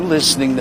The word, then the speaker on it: listening: Are you listening to me? Listening. Fijaos que hay todo is, pero se pronuncian todo es. Listening. Sin la T, no se pronuncia la T, no es listening, sino listening to me.